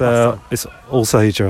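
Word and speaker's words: Alsager: It's Allsager, I think. It's all like it's like as if the A is an O.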